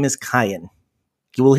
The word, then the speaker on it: Kayan: So, just wanted you to know, before all the messages start coming in, his name is Kyan, but no two people seem to pronounce it the same, and we're all okay with that.